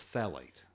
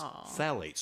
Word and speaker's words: phthalate: And they last about 4 weeks, and they're phthalate free. I'm going to nail that one because I actually, it starts with a PHTH, but it's phthalate.